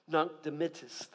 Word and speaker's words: Nunc Dimittis: The Latin words, at least that's the Southern American pronunciation of the Latin. Nunc Dimittis.